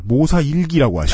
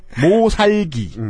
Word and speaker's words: 모살기: 사, 여러분께서 이 책의 제목을 모사일기라고 하시고 그 일기 아니에요? 네, 네. 모살기 음.